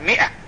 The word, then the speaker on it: مئة: فمثل هذه كذلك الماء ما يقال ماء يقال مئة، ولبثوا في كفهم 300 سنين وزادوا سنة.